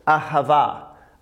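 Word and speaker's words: Ahava (אהבה): and I would pronounce, we pronounce this Ahava. Ahava.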